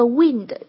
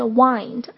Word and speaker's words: wind: Wind down 也是这个 wind 这一个词的拼写哈，但是注意它表示放松的时候的意思啊，这个发音是 wind 而不是 wind。